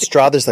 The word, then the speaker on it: Strothers: It's Strothers like brothers.